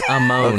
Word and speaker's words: Amon: Am I pronouncing that correctly, everyone? – It's Amon.